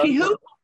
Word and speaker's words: quihoui: And it is Ligustrum, and it's spelled Q-U-I-H-O-U-I. And do you know how you say that? It's no. Kihui. And you have to put the emphasis on the who.